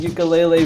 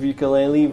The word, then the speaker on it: ukulele: I'm your host, and this episode I'm joined by conservation biologist, herpetologist, and according to our website, ukulele virtuoso. Welcome to the podcast. – Thanks. – you're not a stringent about the pronunciation of ukulele versus ukulele. – No, I'm okay with both.